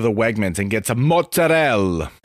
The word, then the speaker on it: mozzarella: And by the way, anyone from Italy, from the country of Italy, would never, ever in a million years say less syllables when the possibility is to say more syllables. It's mozzarella, if it's anything at all. But it's fucking mozzarella.